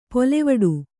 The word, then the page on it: ಪೊಲೆವಡು: polevaḍu